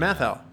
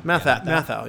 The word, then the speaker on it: Matthau: Matthau. – Matthau? – Matthau, yeah.